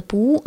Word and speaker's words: Пух: Это был уже совсем другой Пух, хотя все такой же бесконечно очаровательный. Он даже поменял слегка имя, вместо Пуу оно стало произноситься как Пух, что, наверное, сделало его мягче и пушистее.